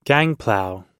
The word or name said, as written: gangplow